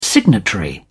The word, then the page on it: signatory: British English